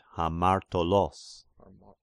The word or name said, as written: Hamartolos